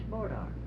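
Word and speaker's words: Bois d'arc: B-O-I, you don't have to spell it? – No, ma'am. – Well, let me spell it for you. You write it down. B-O-I-S. Small D, separate letter. Small letter D. An apostrophe. Capital A-R-C. Now that's Bordark.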